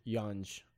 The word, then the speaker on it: Yonge: Y-A-W-N or Y-O-N? – Y-O-N. – Or one. – Yonge. – Oh, there's a G in there? – Yeah. Y-O-N-G-E Street. Yonge.